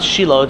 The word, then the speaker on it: Shiloh: But it's always pronounced Shiloh, it's just a question of orthographics, how exactly you spell it.